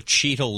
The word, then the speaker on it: Cheetle: Okay, it's not spelled like Don Cheadle, the actor. That's C-H-E-A-D-L-E. This is C-H-E-E-T-L-E. Cheetle.